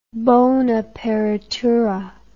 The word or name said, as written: bonaperitura